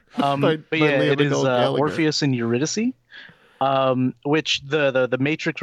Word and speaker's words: Eurydice: Eurydice, which is the Matrix character. – I think the way you should remember this, if you ever forget it, is you think of Morpheus and Andrew Dice Clay.